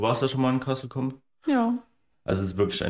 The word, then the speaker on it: Castle Combe: Ja, also haben dann, glaube ich, noch zwei Stunden weitergeschlafen und sind dann zum Castle Comp, Castle Combe, ja. Castle Combe.